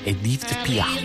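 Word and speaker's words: Édith Piaf: Edith Pia.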